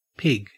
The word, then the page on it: pig: Australian English